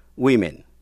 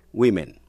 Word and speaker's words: women: Người giám thị, quản đốc, a supervisor, đánh vần, S-U-P-E-R-V-I-S-O-R, a supervisor, người giám thị, người quản đốc. Quý vị cũng để ý đến cách đọc số ít, woman, số nhiều, women. Nghe lại, số ít, woman, số nhiều, women.